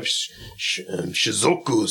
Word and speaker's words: Shizuoka: We have Shizuoka City. – Are you even saying that right? Nigga, you said Shinjuku. You said Shinjuoka. – Shinjuku's a game. So I fucked that up. It's Shizuoka. Shizuoka City.